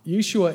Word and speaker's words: Yeshua: Now, Jesus is the English pronunciation of his name. Originally in Hebrew, which was his given name, Mary and Joseph called him Joshua. That's how they would have pronounced it. Joshua, or Yeshua, is a contraction of God's name, Jehovah, or Yahweh, however it was pronounced, and Hoshea, God.